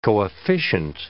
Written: coefficient

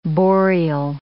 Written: boreal

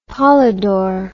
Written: polydor

